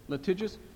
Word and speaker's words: litigious: Litigious, is that the right adjectives? There's another syllable in there. Litigious? Thank you.